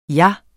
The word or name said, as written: min